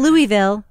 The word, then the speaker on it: Louisville: You pronounced it wrong. It's not Louisville. It's Louisville.